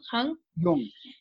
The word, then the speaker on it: Jung: Is it Jung? – Jung. J-U-N-N-G, but pronounced Jung.